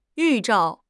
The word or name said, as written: 预兆